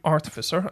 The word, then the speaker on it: artificer: So an engine room artificer, and I'm assuming that I've got the pronunciation of artificer correct. – Yep.